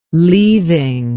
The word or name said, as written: leaving